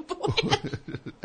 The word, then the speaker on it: boulangerie: You go to the boulangerie.